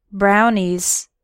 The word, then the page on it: brownies: American English